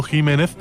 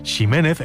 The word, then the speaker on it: Ximénez: Así, por ejemplo, la variedad de una uva conocida como Pedro Jiménez se pronuncia así, aunque ese Jiménez se siga escribiendo con X, si bien también se escucha mucho decir Ximénez e incluso Ximénez cuando se pierde ya la referencia de su pronunciación original.